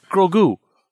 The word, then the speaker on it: Grogu: Pronounced Grogu, not Grogu. Grogu.